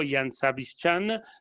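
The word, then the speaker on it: Jan Zabystřan: però col 29 è stato bravo Jan Zabishchan, tra l'altro si pronuncia così, perché ha usato tanto, soprattutto sul finale, è stato bravo a gestire comunque tutti i settori, anche quelli più tecnici.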